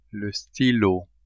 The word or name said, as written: stylo